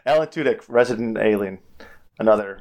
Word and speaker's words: Tudyk: But Alan Tudyk, how do you pronounce his name? I'm sorry. – Tudyk. – Tudyk? Okay, yep. Okay, that's what I thought.